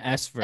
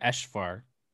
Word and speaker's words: Eshvar: Aparna and Esvar. – Eswar. – No, Esh. It's like Eshvar. I heard her say it. She said it kind of with like an SH sound, I feel like.